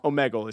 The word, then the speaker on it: Omegle: No, you're right. I think you're right. – Omegle? – Omegle is correct.